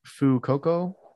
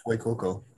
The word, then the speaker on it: Fuecoco: And then the Fire Croc Fuecoco. – Fuecoco. – It's like it. Yeah, it's like Fuego and Coco.